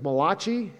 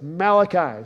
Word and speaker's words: Malachi: I know some of you like to say his name is Malachi. It's not Malachi. If it was a Malachi, he'd be Italian. He's Jewish, so it's Malachi.